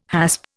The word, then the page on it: hasp: Indian